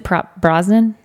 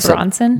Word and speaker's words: Brosnan: Is it Brosnan? Pierce Brosnan. – Did I say it wrong? – I think you said Bronson.